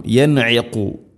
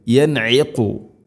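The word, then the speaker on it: യൻഇഖു (ينعق): യൻഇഖു, യൻഇഖു. ഐനും ഖാഫും ഉച്ചാരണം ശ്രദ്ധിക്കണം. തൊണ്ടയുടെ മധ്യഭാഗം ഐൻ, ഖാഫ് ചെറുനാക്കിൻ്റെ ഒരു ഭാഗവും.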